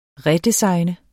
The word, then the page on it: redesigne: ˈʁε-